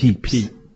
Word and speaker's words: Pepys: Could you pronounce that surname again? – Which one? – Peeps. – Pepys. Pepys or Pepys? – Peeps. – Peeps, you say, is it? – Thank you.